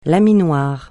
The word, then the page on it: laminoir: francais